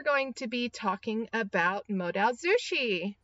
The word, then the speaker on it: Modaozushi: It's Modaozushi, and it's originally a web novel created in October 2015 by the author, who is Mo Xiang Tong Xiu.